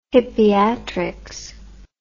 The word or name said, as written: hippiatrics